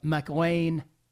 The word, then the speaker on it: McLain: For my own social media accounts, if you are on Twitter, all you need to do is go to at Bob McLain 8, the number 8. And by the way, my last name is spelled M-C-L-A-I-N.